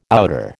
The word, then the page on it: outer: American English